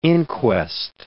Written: inquest

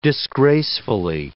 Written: disgracefully

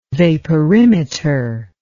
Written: vaporimeter

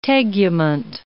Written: tegument

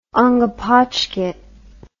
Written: ongepotchket

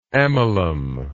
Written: amylum

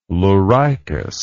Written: leuricus

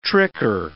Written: tricker

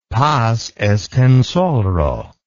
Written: Paz Estenssoro

